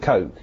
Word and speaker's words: Koch: That would be K-O-C-H, is it? – Yeah, so he pronounces it Coke himself, right.